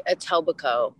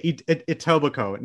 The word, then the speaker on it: Etobicoke: How do you say the name? Like, it starts with an E. – Etobicoke. – Okay, Etobicoke. Now, and obviously now you're in the City of Angels.